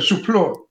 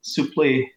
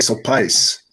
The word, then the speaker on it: Suplee: I pronounce it Suplee. – Suplee. Yeah. Suplee. And the French do another really weird version. I think they say Suplice.